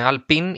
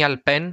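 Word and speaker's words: Alpine: Ε, Αλπίν ή Αλπέν, με ρωτάνε πώς προφέρετε, αν είστε Γάλλοι, Αλπέν, αν είστε Έλληνες ή Άγγλοι, Αλπίν.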